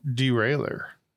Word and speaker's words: derailleur: It is a French word that is pronounced derailleur.